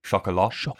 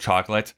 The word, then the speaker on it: Chocolat: And then he'd go on to make this movie, Chocolat, I believe is how you say it. Chocolat. Instead of chocolate.